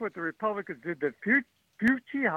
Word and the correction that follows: Fauci: how do you say that name? – Fauci.